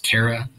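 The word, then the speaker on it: Chera: He comes from a planet, I think it's pronounced Kera. It's C-H-E-R-A, I believe.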